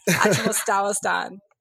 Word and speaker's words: Achinostawinon: So how do you pronounce that correctly so they know? – Oh, my God. – So I know. – I'm not even going to pronounce it correctly because I have a horrible Southern accent, according to some of my teachers. But it's Achinostawinon.